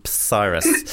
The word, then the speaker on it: Psyrus: We were called Cyrus with a silent P. At the front, it was Psyrus.